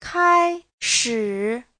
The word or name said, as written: kai1shi3